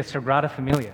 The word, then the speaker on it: Sagrada Familia: And we're visiting the Sagrada Familia.